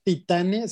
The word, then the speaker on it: Titane: están en el lugar correcto con Titán. – Nada, nada, más para hacer la aclaración, es Titane, se escribe Titane porque no es como Titán de yo soy Titán.